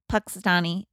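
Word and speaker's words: Punxsutawney: They're in Puxtony, Pennsylvania.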